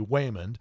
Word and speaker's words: Waymond: and I didn't mispronounce that. His name is actually Waymond, and he's played by Ke-Hui Kwan.